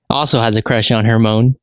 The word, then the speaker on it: Hermione: So Harry Potter is a witch who has recently enrolled in Hogwarts High School and he just met Hermione. And he has a crush on Hermione, right?